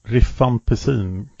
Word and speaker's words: rifampicin: Ja, för själva botlingen då, det sker ju med en typ av antibiotika och det är då med dapson och rifampicin. Uttalar jag det rätt där? – Rifampicin kanske? – Ja. Rifampicin.